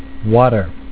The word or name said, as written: water